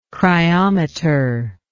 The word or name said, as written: cryometer